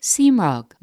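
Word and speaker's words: seamróg: It's a kind of slurred pronunciation of the Irish word simrog, meaning little clover.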